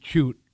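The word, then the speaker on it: Tuite: So a lot of people call it Toots because Rick's name is Tui, and it's T-U-I-T-E-S, I believe. – Yeah. Actually, you know, the Irish pronunciation would be Chute.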